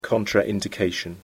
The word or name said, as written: contraindication